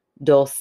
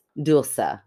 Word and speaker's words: Dulce: So, she says that all of her American friends with American accents call her Dulce, D-O-L. Dash S-A-Y, Dulce. – Yes. Phonetically, that's how you spell phonetically. It. In Spanish, it's Dulce, which is beautiful. Okay, because it's spelled D-U-L-C-E, but I didn't know how to pronounce it.